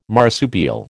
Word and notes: marsupial: American English

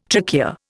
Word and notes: trachea: Indian

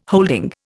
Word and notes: holding: Indian

